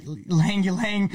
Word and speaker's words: Ylang Ylang: And so this is called the Latitude Night Shift, and it's a Ylang Ylang, I'm not sure if I said that right, Ylang Ylang, yeah.